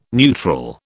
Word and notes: neutral: British English

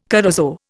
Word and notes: corozo: Indian

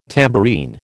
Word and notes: tambourine: American English

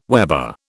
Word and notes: weber: British English

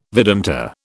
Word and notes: Vedanta: British English

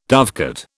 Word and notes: dovecote: British English

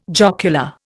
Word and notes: jocular: Indian

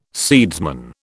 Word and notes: seedsman: British English

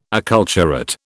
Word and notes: acculturate: British English

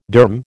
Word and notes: derm: American English

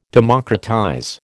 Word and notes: democratize: American English

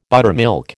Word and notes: buttermilk: American English

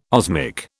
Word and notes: osmic: British English

